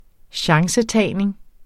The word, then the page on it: chancetagning: -ˌtæjˀneŋ; -ˌtæjneŋ